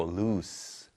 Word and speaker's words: loose: 요거는 lose가 아니고 loose 발음이 납니다.